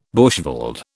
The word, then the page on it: bushveld: British English